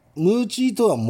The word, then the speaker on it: 鬼餅: 沖縄方言ではムーチーとは餅のことです。沖縄では漢字で鬼餅と書いてムーチーと呼びます。